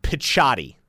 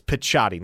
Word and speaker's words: Picciotti: So I exchanged messages with his mom, and she was like, it's got that Italian C sound, Picciotti. That's how you say it. It's Picciotti.